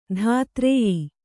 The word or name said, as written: ಧಾತ್ರೇಯಿ